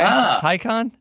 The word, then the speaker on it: Taycan: Yeah, it rhymes with icon, according to Edmonds. – Okay, Taycan.